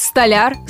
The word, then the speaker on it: столяр: Ударение на «я». И во всех однокоренных словах тоже будет на «я». Значит, у нас выходит столяр, столярский инструмент, столярная мастерская, а вот при изменении по падежам ударение перейдет на окончание.